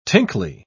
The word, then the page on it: tinkly: British English